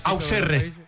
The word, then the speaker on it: Auxerre: Auxerre.